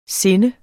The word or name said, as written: sindet